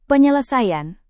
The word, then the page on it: penyelesaian: indonesian